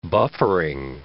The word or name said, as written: buffering